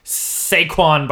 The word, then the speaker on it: Saquon: And speaking of great names, that takes us to the NFL Combine, where you can watch such future stars as Saquon Barkley. Am I saying that right? – I think so, yeah.